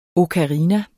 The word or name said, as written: OKARINAER